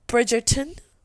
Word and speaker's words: Bridgerton: I started watching Bridgerton. That's the way you're supposed to pronounce it.